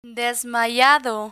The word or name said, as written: desmayado